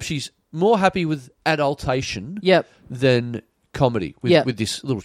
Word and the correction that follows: adulation: Adulation? – Adulation, you're adulation.